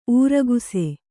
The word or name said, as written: ಊರಗಸೆ